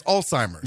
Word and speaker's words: Alzheimer's: Do you put a T in pizza? Because that's the same sound in pizza as Alzheimer's. – Yeah, spell pizza phonetically. – P-I-Z-Z-A.